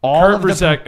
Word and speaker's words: Busiek: Kurt Busiek and Mark Bagley.